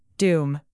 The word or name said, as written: doom